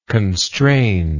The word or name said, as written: constrained